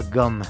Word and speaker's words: Gaume: Él se llama Gaume, se escribe Gaume o Gaume, pero se pronuncia gum.